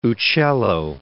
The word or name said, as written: Uccello